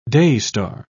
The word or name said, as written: daystar